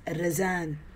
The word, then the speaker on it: Razan: Okay, so my name is pronounced as Razan. – Razan. – Razan. So it's actually R-A-Z-A-N, despite what it's known as today as Rozan, R-O-Z-A-N.